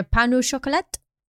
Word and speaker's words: pain au chocolat: Yeah, well, I've always known pan au chocolat. Is that how you say it? – Yeah, pan au chocolat.